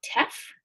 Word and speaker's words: Teff: And your teffles, your waffles made with Teff, did I pronounce that right? – Yeah, you did.